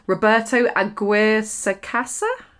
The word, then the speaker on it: Roberto Aguirre-Sacasa: Sabrina is a 10-episode Netflix show developed by, and I'm going to get his name wrong, Roberto Aguirre-Sacasa? – Well done.